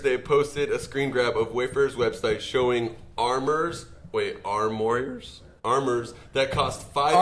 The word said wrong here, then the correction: armoire: armoire. – Figures. Oh, really? – It's a French word. We are crushing the French pronunciation.